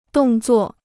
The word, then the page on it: 动作: dòng zuò